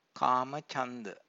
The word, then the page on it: kamachanda: Pali